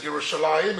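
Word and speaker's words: Yerushalayim (ירושלים): First of all, it's important to know that when it's written in the Tanakh, even though we pronounce it Yerushalayim, it's written Yerushalem.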